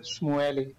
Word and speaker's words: Shmuely: You pronounce it Shmuely.